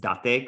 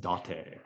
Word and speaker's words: Date: It's Date, because it has to do with the region and old regional naming up there. You can be that asshole at the party who's like, it's Date. It's actually pronounced Date.